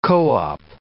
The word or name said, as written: co-op